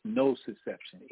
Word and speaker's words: nociception: I'm going to assume that C right there is not a hard C like nociception. I don't think that's nociception. – And I don't think it's nociception. I believe it's nociception. Either way, it's spelled N-O-C-I-C-E-P-T-I-O-N, nociception.